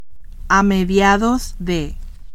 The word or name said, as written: a mediados de